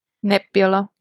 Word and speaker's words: Nebbiolo: How do you say that? Is that right? – Nebula, yeah.